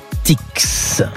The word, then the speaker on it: Artix: Artix. On prononce bien le X de Artix, comme Astérix. Et courez-y si vous êtes dans le coin d'Artix, Pyrénées-Atlantique.